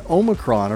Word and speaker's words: Omicron: Both Omicron and Omicron are fine. The Oxford Dictionary includes Omicron. – Hmm. – And in classical Greek, it was pronounced with the second syllable sounding like me. – Right. – Omicron.